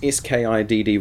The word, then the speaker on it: Skitty: Well, yeah, it's pronounced Skiddy, but it's spelled Skitty.